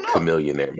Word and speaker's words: Chamillionaire: I've never heard that pronunciation. – Chamillionaire. Chamillionaire? That's, that's crazy. That's crazy. – Isn't that how you say it? You being serious? – Is it? I've heard chameleonaire my entire life. – It was chameleonaire. Yeah, that's what I. That's what I thought.